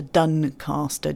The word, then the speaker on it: Doncaster: So in the UK, we've had Doncaster transformed to Duncaster, D-U-N. – That is how it's pronounced, to be fair.